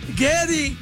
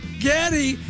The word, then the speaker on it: Geddy: Gary will become known as Geddy, playing on Gary's mom's heavily accented pronunciation of his name. Geddy! Geddy! After